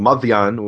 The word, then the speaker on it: Madian: actually it's called Madian, which is the Arabic pronunciation, but Madian is obviously Midian in Hebrew.